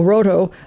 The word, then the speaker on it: Rohto: They pronounce it Roto? – I guess, R-O-H-T-O, Roto, yeah.